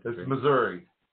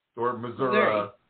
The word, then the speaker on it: Missouri: It's Missouri. Or Missouri. Yeah, it goes both ways.